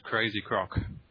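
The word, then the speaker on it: Krayz Croc: Well, it is actually pronounced Crazy Croc.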